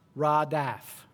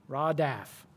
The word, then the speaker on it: radaf: The word follow here, there's a Strong's word for it, and it's pronounced radaf, radaf.